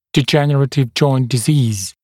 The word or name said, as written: degenerative joint disease